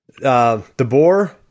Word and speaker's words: DeBoer: DeBoer, is that how you say his name? – Yeah.